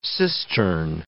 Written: cistern

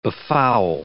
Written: befoul

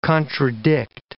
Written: contradict

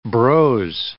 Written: brose

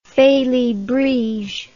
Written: felibrige

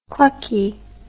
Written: clucky

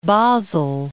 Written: Basel

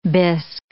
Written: bisque

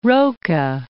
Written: Roca, Cape

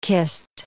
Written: kist

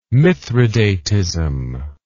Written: mithridatism

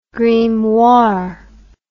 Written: grimoire

